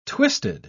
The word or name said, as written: twisted